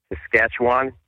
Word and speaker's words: Saskatchewan: Quebec, Ontario, Manitoba, if I say it right, Saskatchewan. How do you say it? – That's perfect. Yeah.